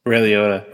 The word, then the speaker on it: Liotta: And also Blackbird, which has Taron and the actor who passed away, Ray LaToya. Did I say his name? Right? He was on Cocaine Bear. – Ray Liotta. – Ray Liotta.